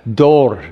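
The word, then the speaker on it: dor (דור): But it was fascinating for me to discover what the word generations is in the Hebrew, and it pronounces pretty much like I've alliterated it up there, dor, with a little bit of a roll on the R at the end. Try it. Dor, like play dor.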